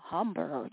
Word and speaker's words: Hember: And the artist's name, his first name is spelled H-E-M-B-E-R. Humbre, Humbre, Humbre. Yeah. I hope I said that right, y'all. So just in case, let me spell that out for you. You know, the H is silent. H-E-M-B-E-R, Humber, you know what I mean?